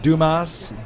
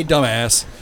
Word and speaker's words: Dumas: I'm sure it's pronounced Dumas, D-U-M-A-S, but we like to say dumbass.